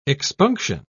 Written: expunction

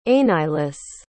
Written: aineislis

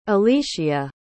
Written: alyshea

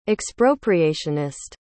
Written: expropriationist

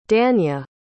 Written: dania

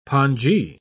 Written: pongee

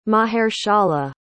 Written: mahershala

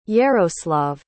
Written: Yaroslav

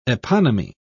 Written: eponymy

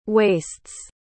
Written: waists

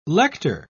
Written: lector

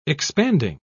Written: expanding

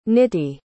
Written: niti

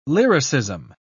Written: lyricism

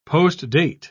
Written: postdate

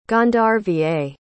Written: gandharva

